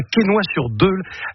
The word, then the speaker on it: Quesnoy-sur-Deûle: Tu habites à Quesnoy-sur-Deûle. Est-ce que je l'ai bien prononcé? Je n'ai pas écorché le nom. – Impeccable.